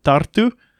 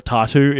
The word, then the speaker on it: Tartu: So I was staying in the capital for a couple of days before I headed down to Tartu, or Tartu, if you're unable to roll your R.